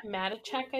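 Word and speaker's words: Matichak: And the daughter, Andy Michicek, huh? I can't pronounce her name. – Matichek, I think. – Matichek. So what, the H is silent? – Matichek. Well, I mean, it's there. Check. Matichek.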